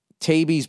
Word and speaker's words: Taibbi: Check out Matt Taibbi's book, Hate Inc., pronounced Taibbi. Okay, TIE-EE-BEE.